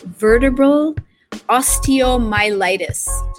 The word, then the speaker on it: vertebral osteomyelitis: I believe you were diagnosed with a medically incurable condition called vertebral osteomyelitis.